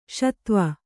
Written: ಷತ್ವ

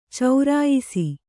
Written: ಚೌರಾಯಿಸಿ

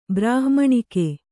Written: ಬ್ರಾಹ್ಮಣಿಕೆ

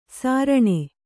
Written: ಸಾರಣಿ

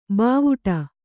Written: ಬಾವಟೆ

